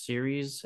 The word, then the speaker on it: Ceres: C-R-E-S. – C-R-E-S? Series. – C-E-R-E-S. Series and Zagreus or Minerva and Pallas.